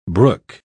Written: brook

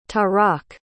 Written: tarak

